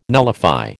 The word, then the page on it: nullify: American English